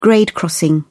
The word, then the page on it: grade crossing: British English